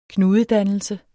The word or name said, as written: knudedannelse